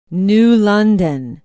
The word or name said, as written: New London